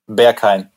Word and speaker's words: Berghain: So the first one, excuse my German pronunciation, so sorry, Berghain and Panorama Bar. How is that? – Yeah, Berghain.